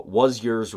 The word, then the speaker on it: Wuzyerz: And then finally, my favorite one was called the Was Yours Repo. – Okay. – A repossession service. It is spelled W-U-Z-Y-E-R-Z, repo. Was yours.